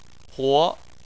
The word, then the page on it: huo: 2